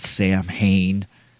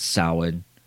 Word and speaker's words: Samhain: I really wish that these supposed Halloween know-it-alls, though, in the movie, you know, like Loomis and whatnot, knew how to pronounce Samhain. It's not Samhain, it's Samhain.